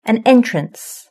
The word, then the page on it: an entrance: British English